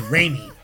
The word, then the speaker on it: Raimi: You get his name right, all right? It's Raimi.